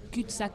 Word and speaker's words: cul-de-sac: ¿Cómo lo pronuncias tú? – Cul-de-sac. – Claro, así, con acento francés de verdad, no con acento de Burgos, pues, nosotros incapaces de eso.